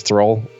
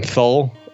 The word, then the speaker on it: Thrall: But yeah, they had this whole like viral marketing thing where there's a character that they could not pronounce properly because their heavy accent, which is Thrall, who's a badass orc. So they end up saying Thull all the time, and it just kind of became like this meme amongst WoW people, and it just became a joke.